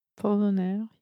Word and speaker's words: Apollinaire: Some French name, Apollinaire. – Apollinaire, yeah.